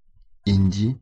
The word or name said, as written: hindi